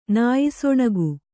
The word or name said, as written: ನಾಯಿಸೊಣಗು